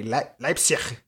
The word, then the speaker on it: Leipzig: Leipzig.